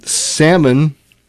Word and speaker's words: salmon: Salmon, do not pronounce the L.